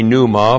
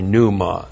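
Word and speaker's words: pneuma (πνεῦμα): Now, you say pneuma, but no self-respecting Greek would ever say pneuma. There are no silent letters in Greek. It's pneuma. So we have the verb pistuo. P-I-S-T-E-U long O.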